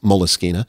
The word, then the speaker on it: Moleskine: Jason, thank you for pronouncing Moleskine correctly. So many people refer to those as moleskin notebooks, but it actually is Moleskine.